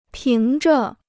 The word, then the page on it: 凭着: píng zhe